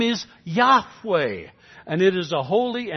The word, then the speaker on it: Yahweh: We have learned to pronounce it that way because of the influence of the German language on our English language. But in the Hebrew alphabet, there is no J and there is no V. They couldn't have pronounced Jehovah.